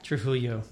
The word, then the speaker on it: Trujillo: Trujillo.